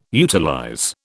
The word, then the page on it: utilize: British English